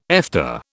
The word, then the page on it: Efta: British English